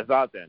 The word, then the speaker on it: zazen: Yeah, zazen, yeah. It's because in Japanese all the A's are pronounced but anyway, it's just a practice of zazen.